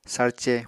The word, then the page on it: sárce: apatani